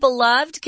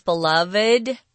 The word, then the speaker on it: beloved: This word beloved can also be pronounced beloved, beloved, or beloved.